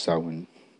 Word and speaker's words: Samhain: The festival of Samhain. It's actually pronounced Samhain.